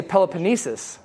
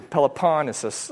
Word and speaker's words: Peloponnesus: I used to say Peloponnesus. But it's actually, I looked up the pronunciation. Everyone puts the emphasis on the pon. So I'm trying to. Peloponnesus.